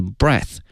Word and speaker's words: breath: 同时啊，呼吸这个词因为呢它的词性是名词，因此它的发音是 breath，而它的动词呢是 breathe。